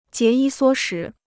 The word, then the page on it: 节衣缩食: jié yī suō shí